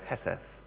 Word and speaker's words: hesed: Steadfast love translates a single word in the Hebrew, the word hesed. If you want to write that down, H-E-S-E-D, but the D's pronounced like a T-H.